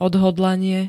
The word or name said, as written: odhodlanie